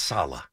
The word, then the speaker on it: Saleh: Can we get his name right? It's Saleh.